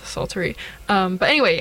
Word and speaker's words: psaltery: It's the silent P, I assume, but psaltery.